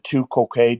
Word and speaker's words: tu quoque: Is it tu quoque, David? Is that how you pronounce it? T-U. Q-U-O, yeah, Q-U-O-Q-U-E.